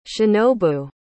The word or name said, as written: shinobu